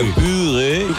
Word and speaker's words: purê: Escreve-se purê e como é francês, que é pire. Pire. É pire mesmo.